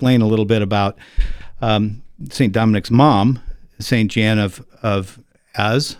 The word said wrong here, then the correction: Jane of Aza: I think I said it right. Az? – Jane of Aza. – Jane of Aza.